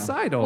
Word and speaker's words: sidle: And saddle up. Cameron was a very, he didn't want to be removed. removed from the restaurant. – Sidle up? – It's Sidle. – Will you spell it for us? S-I-D-L-E.